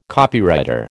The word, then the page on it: Copywriter: American English